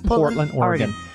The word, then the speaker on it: Portland, Oregon: Yes, Portland, Oregon.